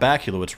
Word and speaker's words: Bakula: You also like Scott Bakula, which rhymes with Dracula.